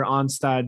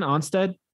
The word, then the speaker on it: Onstad: Pat Onstad. It's an A. – Is it? – Yes. O-N-S-T-A-D.